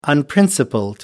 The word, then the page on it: unprincipled: American English